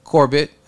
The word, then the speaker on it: Corbett: So Terry Corbett.